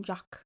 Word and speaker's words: Jacques: Is that how you say his name? Jacques? – I call him Jacques.